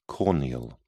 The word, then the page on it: corneal: British English